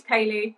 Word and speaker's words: Ceilidh: Yeah, my name's Ceilidh, but I spell it. It's the Gaelic way. It's blame the parents. It's spelled CEILIDH.